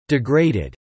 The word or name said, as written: degraded